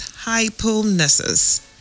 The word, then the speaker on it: hypomnesis: Now, the second word I will be giving you is hypomnesis. Hypomnesis. Now, that word is spelled H-Y-P-O-M-N-E-S-I-S. Hypomnesis. H-Y-P-O-M-N-E-S-I-S.